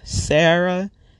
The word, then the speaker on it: Sarah: So like on a job application or any sort of application I would fill out, I'd use my first name, Sarah, spelt with an H.